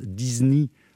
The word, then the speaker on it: Isigny: Et d'Isigny, ça se prononce Disney en américain, et donc le Walt Disney.